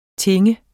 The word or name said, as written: tinge